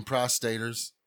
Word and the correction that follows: prognosticators: I mean, prognosticators.